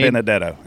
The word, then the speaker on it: Benedetto: He sent me the way to pronounce it. – Yeah. – He texted me exactly how to say it. Matt, you know what we need? Benedetto. And now I got it.